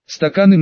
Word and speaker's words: стаканы: «Стаканы нам принеси», сделав ударение «ы» в слове «стаканы», потребовал второй.